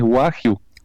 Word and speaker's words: Wagyu: Bueno, pero igual, viste que tiene diferentes formas para pronunciar, pero bueno, bien la forma correcta es Wagyu.